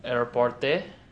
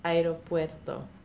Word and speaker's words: aeropuerto: Aeroporte. La... Aeroporte. – Aeropuerto.